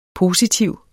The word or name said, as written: positiv